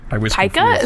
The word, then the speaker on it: Pica: Our weird disease of the week is Pika. P-I-C-A, not P-I-K-A like the Pokemon. It is when you... – Pika? – Is that how you say it? – Pika, yeah.